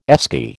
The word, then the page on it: Esky: American English